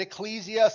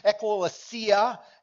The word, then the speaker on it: ekklesia: I'll put it up on the screen, and some people pronounce it ecclesia, some ekklesia, for some would say it that way.